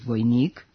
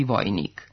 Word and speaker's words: vojnik: U srpskom standardnom književnom jeziku postoji pravilo da u unutrašnjim slogovima višesložnih reči ne mogu stajati silazni akcenti. Stoga nećemo reći devojka i vojnik, kako se kaže u nekim našim dijalektima, već devojka i vojnik.